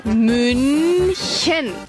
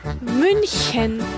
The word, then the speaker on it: München: La U umlaut es poniendo los labios como si quisieras decir una U, pero pronuncias I. U, U, U. La CH como una J sonriente. München. München.